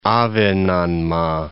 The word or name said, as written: Ahvenanmaa